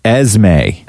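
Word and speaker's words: Esmée: Yeah, but she spelled it E-S-M-E-E. – Hold on, I got to write this down. – E-S-M-E-E. – Esme? – Esme. – Esme. – Esme is how it's pronounced.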